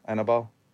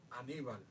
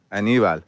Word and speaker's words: Aníbal: How do you say the name? Annabelle? Remember Annabelle from the Taínos? – Oh, Aníbal. – Aníbal. Mm-hmm. So those were the characters, because I obviously don't pronounce it correctly.